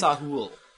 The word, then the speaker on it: Sahul: which was called the Sahul Continuum. I hope I pronounced that correctly. – I think it's Sahul. The something or other continuum. – S-A-H-U-L.